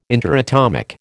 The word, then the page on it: interatomic: American English